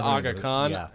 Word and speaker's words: Aga Khan: Aga Khan? – The Aga Khan.